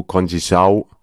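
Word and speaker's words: Conceição: Und kurz vor Schluss Francisco Conchisau mit dem 3-0 Endstand. – Ist das die Aussprache? – Ich glaube, das ist genau so die Aussprache.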